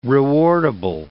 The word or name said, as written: rewardable